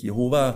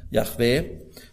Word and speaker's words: Jahwe: Also hier wird Jahwe, der Gottesname im Alten Bund, manche übersetzen fälschlich Jehova, das heißt aber, also J-H-W-H sind die hebräischen Buchstaben, die vokalisiert werden. Jahwe ist die höchstwahrscheinlich richtige Aussprache. Also das sagt Jahwe, mir sollen sich alle Knie beugen.